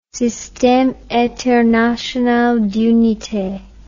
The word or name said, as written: systeme international d'unites